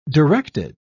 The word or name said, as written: directed